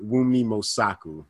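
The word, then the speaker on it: Wunmi Mosaku: I was mispronouncing her name last episode. I was butchering it. But Mumi Mosaku.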